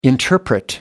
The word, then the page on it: interpret: American English